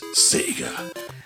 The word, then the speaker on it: Sega: And obviously there was a battle, I think, all throughout our childhood, which was Nintendo versus Sega, right? – You got to say it right. Sega!